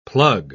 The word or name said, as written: plug